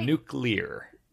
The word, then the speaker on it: nuclear: Nuclear? Am I saying that right? – Nuclear. – Nuclear. – Nuclear, yeah. I remember Homer Simpson and George W. Bush say nuclear.